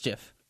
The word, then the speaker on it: GIF: It's spelled GIF, but it's GIF.